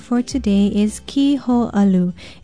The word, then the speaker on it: kiho'alu: Be sure to put in the kahako or makron over the I and O and pronounce it kiho'alu.